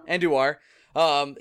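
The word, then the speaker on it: Andújar: Adjuar? I can't say. – Andujar? – Andujar.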